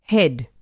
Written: हैड

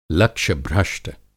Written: लक्ष्यभ्रष्ट

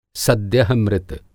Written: सद्यः मृत

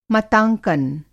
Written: मतांकन

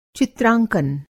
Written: चित्रांकन

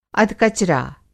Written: अधकचरा

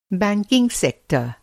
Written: सेक्टर

